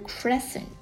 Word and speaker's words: crescent: 好，接下来学习一个单词 crescent，拼写一下 C-R-E 读作 cre，然后 S-C-E-N-T cent crescent，连起来呢这个词是新月的意思，比如说当他离家的时候天空中有一弯新月。